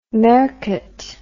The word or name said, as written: mercat